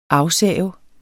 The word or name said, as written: AFSAV